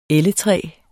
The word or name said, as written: elletræ